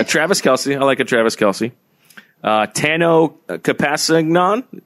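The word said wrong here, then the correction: Kpassagnon: Well, the K, I believe, is silent. – Tano Pasignan.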